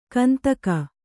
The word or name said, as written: ಕಂತಕ